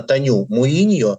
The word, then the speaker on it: Таню Муиньо: Правильно говорить, именно Таню Муиньо, которая начинала с того, что снимала видеоклипы Монатику, а теперь она уже признанный клипмейкер с мировым именем.